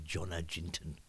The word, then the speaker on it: Jonna Jinton: Yona Yintong, if you want the pronunciation, or if you're from the UK, you probably say Jonna Jinton.